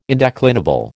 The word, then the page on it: indeclinable: American English